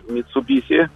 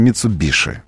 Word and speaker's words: Mitsubishi: Rus Mitsubishi, как правильно говорится, потому что деятельные лидеры общественного мнения утверждают, что в японском языке буквы «ш» нет. Может, ее там и нет. Но в нашей транскрипции произносится Mitsubishi. Вот, ну, а не так. Это сам японец говорит. А аутентичное произношение для русских пользователей автомобилей – Mitsubishi.